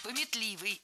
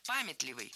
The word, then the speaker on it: памятливый: Пометливый – это неверно, правильно, памятливый. Ударение в этом слове ставим на первый слог. В качестве шпаргалки можно держать в голове слово «память».